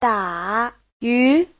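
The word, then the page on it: dayu: da3yu2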